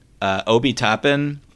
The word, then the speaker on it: Toppin: If I'm Cleveland, I also want a wing, but Obi Toppin...